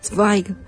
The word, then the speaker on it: Zweig: Zweig, que es Z-W-E-I-G, pero sí, se pronuncia como dice. – Yo me fijé cómo se pronunciaba, y decían que se pronunciaba, me corregirán, por favor, Zweig. – En alemán, Zweig.